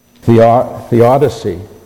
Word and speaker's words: theodicy: The topic that we're talking about in theology is called theodicy.